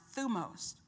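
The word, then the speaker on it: thumos (θυμός): That word wrath in the Greek is the word thumos, or it's pronounced thumos, rage.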